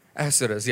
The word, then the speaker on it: Ahasuerus: So King Ahasuerus, I hope I pronounce it right. Ahasuerus, right? Ahasuerus, yeah.